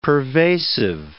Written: pervasive

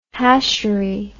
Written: hashery

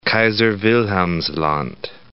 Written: Kaiser-Wilhelmsland

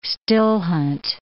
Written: still-hunt

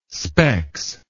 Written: specs

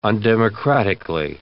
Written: undemocratically

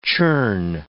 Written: churn

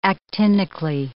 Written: actinically

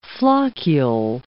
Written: floccule

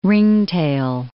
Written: ringtail

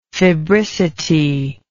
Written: febricity

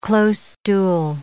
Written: closestool

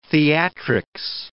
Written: theatrics

